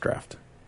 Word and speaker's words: draught: D-R-A-U-G-H-T is pronounced draft.